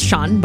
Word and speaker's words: Sian: If you ever find yourself texting someone from Wales, don't tell them that you're Sien, because the word Sien, pronounced Shan but is spelled S-I-A-N, is actually the Welsh version of the name Jane.